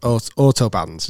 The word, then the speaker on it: Autobahn: So if you don't know this, in Germany the motorways are called autobahns. Is that have I pronounced that correctly? – Yeah, autobahn. Yeah.